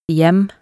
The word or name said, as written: hjem